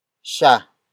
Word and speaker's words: siya: Tumalon is spelled as T-U-M-A-L-O-N. It means jumped, or the act of jumping has happened. The next word is siya. Siya. Siya. Siya is spelled as S-I-Y-A.